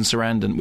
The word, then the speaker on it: Sarandon: They're then going to get married, but then she is thrown down a well by evil stepmother, voiced by Susan Sarandon, which rhymes with abandon, and she ends up in New York.